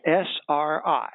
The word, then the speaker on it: SRI: SRI. ESG means whatever. It means ever so good or something. – Environmental, social, and governance. – Ah. And RSI is or SRI, you say it like Sri Lanka, except what it means is socially responsible investing.